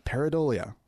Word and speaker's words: pareidolia: Thank you, Wendy, for correcting my pronunciation. Pareidolia is, I guess, how you're supposed to say it.